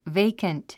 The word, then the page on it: vacant: véikənt; ベイカント